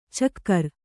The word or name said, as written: ಛಿಕ್ಕಾರ